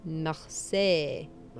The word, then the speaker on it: Marseille: Marseille.